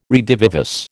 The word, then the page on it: redivivus: American English